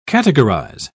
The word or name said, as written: categorize